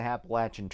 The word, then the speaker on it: Appalachian: because he hiked the Appalachian Trail.